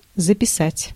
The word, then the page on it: write: /ɹaɪt/